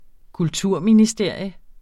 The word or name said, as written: kulturministerium